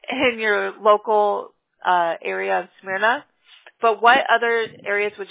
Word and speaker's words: Smyrna: We're obviously going to get you found in your local area of Smyrna. Am I pronouncing that right? Smyrna? – Don't overthink it. Smyrna. – Smyrna. Okay. – Pronounce it like it has a U instead of a Y.